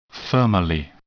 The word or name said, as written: thermally